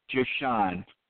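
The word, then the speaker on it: Ja'Shon: It's the main character's name is Jashawn. Jashawn. J-A-apostrophe-S-H-O-N. – Jashawn.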